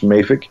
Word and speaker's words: mafic: You said mafic. It's actually pronounced mafic.